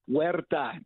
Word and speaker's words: Huerta: And you said Huerta, right? Like they do in Mexico.